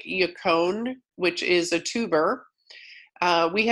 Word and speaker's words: yacon: And it's spelled Y-A-C-O-N. Doesn't even rhyme with bacon, which is sad, but it's yacone.